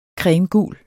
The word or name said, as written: cremegul